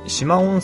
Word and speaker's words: 四万: ちょっと北に行くと四万温泉っていうのがあるんですよ。四万って書いて四万って読むんですけども、この温泉街の中に石前館っていうお宿があるんですね。